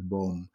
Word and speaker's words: boom: That would be boom in English, but we pronounce it as boom.